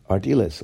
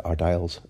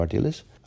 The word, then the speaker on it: Ardiles: would be Ardiles, although we sometimes pronounced it Ardiles at the time.